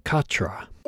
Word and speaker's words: Cartref: This is spelt Cartref, but listening to the track, I think it's pronounced Cartra.